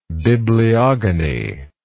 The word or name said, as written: bibliogony